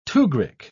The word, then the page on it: tugrik: British English